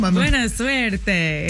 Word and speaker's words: Buena suerte: Buena suerte.